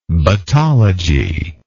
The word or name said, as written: battological